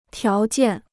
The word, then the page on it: 条件: tiáo jiàn